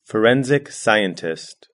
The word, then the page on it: forensic scientist: British English